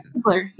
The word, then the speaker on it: Ziegler: And does she pronounce her last name Ziegler, or how does she? – Ziegler, yeah.